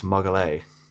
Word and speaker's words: Magalhães: I don't know how to say his last name. – I believe it's Magalhães. – Magalhães. All right, that's a little bit easier.